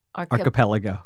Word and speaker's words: archipelago: Wait, ar- archipelago. – archipelago. Archipelago. – Yeah. – I already knew what that word's pronunciation was.